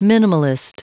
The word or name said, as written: minimalist